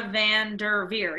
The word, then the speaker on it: VanDerveer: Tara VanDerveer? Am I saying that right? – Hold on. Let me see. – Tara VanDerveer. Yeah, Tara VanDerveer from Stanford.